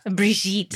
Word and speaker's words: Brigitte: Brigitte.